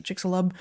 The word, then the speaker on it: Chicxulub: Chicxul, I never pronounce it. Chicxulub.